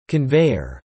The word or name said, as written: conveyor